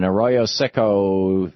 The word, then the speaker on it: Arroyo Seco: Steven in Arroyo Seco, New Mexico. Am I saying that right, Steven? – Yeah.